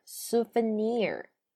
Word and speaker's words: souvenir: 纪念品在这里跟大家复习一下，它的英文读音是 souvenir，s o u v e n i r，souvenir。